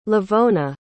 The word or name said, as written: levona